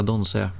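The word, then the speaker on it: Donsö: Jag någon gång bara helt vilse ute i Göteborgs skärgård och inser att jag är nära Donse, som man uttalar det.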